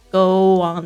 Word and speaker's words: go on: Go on the trip，特别注意一下，你要呃小心这个 go on，这个你不要念成 go on，go on，OK，这个连元音跟元音之间连在一起的时候，其实它中间会有一个这个我的声音。 Go on。